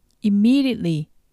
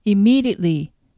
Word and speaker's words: immediately: immediately は音節に分けると im, me, d, ate, t h の 5 つになります。